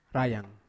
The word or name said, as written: rayan